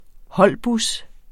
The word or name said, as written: holdbus